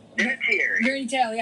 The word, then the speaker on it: Vinatieri: Vinatieri.